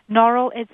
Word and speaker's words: Norrell: Is it Norrell or Norrell? – Norrell is how I say it, although I'm really not that fussy.